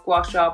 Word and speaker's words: gua sha: And then the brand Hyu Method got in contact with me and they were like, oh, would you like to offer our gua sha products and the oils?